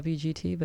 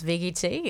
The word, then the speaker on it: VGT: I keep saying the WGT, but that's not correct, I guess. – Yeah. – Thanks for having us. We caught it from our friends. VGT is how they've been pronouncing it. And now that's how we do too.